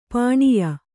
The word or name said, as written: ಪಣ್ಯ